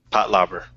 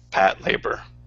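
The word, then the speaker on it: Patlabor: I'm pretty sure you're saying it wrong. It's mobile police... – Pat Labor. – Yeah, Pat Labor is probably more accurate.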